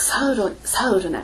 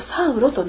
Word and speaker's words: サウル: 英語ではサウルなんです、サウロではなくサウルなんです。なぜかというとヘブライ語でサウルは、サウルなんですがこれをギリシャ語で発音するとサウロとなるので日本語の聖書はどっちも取ってしまってるんですね。